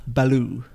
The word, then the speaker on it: Baloo: Baloo. – Okay. Right. – Wait, what? – I thought you said blue, like the colour. – Wait, isn't that not it? Blue bear? – No, it's Baloo.